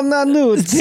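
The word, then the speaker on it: Nannuzzi: Armando Armando Nanunzi. Nanuzzi? Um, I think you mean Armando Nanuzzi. – Thank you. I was so close to the pronunciation, but uh, like I love those like um, oh, and Dino De Laurentiis did uh, produce Manhunter and stuff too.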